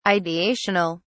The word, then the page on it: ideational: British English